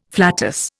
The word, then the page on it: flatus: Indian